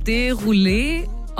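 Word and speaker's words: Desrouleaux: Und sein Nachname wird, ich glaube, Derulé ausgesprochen. Derulé? Also geschrieben wird Desroleux. Ja.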